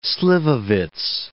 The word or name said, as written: slivovitz